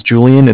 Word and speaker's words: Julian: My name's Julian. It's J-U-L-I-A-N. And I'm calling with Bank of Cardiff. It's Cardiff is spelled C-A-R-D as in David, I-F-F as in Frank Frank.